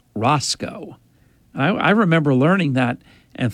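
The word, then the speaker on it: Racicot: I think his first name was Mark, but I do know his last name, it was pronounced Roscoe, which was very strange.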